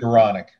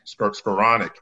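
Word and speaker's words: Skowronek: You've got some experience and size and a Javon McKinley and Ben, I always mess up his name, Skoronek. I guess that's how you pronounce it. – Skoronek. – Skoronek.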